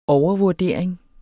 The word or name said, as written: overvurdering